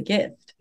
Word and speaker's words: GIF: I mean, if you add a T to that, how do you pronounce that? – That's true. At the end. You pronounce it GIF.